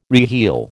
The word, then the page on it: reheel: American English